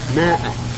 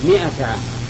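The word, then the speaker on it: مائة: في ناس الآن يقولون 100 عام كيف ينطقون بها؟ ماء، سبحان الله، الميم مكسورة وانت تقول ماء، هذه اللغة ما هي لغة عربية. 100 عام، ومن قال ماء في القرآن فقد لحن لحنا يجب عليه أن يعدله.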